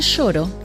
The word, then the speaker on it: choro: El choro, que se escribe con C al comienzo y se pronuncia sh, es un estilo de música brasileña muy antigua que se desarrolló en Río de Janeiro en 1870.